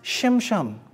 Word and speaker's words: Shimshon: In fact, if you want to get the actual name of Samson right, in Hebrew, it would be pronounced Shimshon.